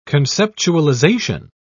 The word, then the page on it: conceptualization: British English